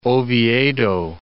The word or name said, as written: Oviedo